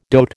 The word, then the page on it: dote: American English